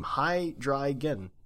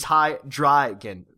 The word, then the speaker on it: Hydreigon: That's how you pronounce it. That's his name. Hydreigon. Hasn't the poor thing been through enough with the introduction of fairy type? It's Hydreigon. Because of the dry is German word for...